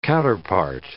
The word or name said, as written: counterpart